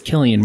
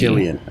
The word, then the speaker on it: Cillian: All right, we have, is it Cillian? I never know how to pronounce his name. – Yeah, I think his name is Cillian. – Cillian Murphy. – I thought it was Killian Murphy. – Killian. I think it's Killian.